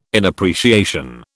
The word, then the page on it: inappreciation: British English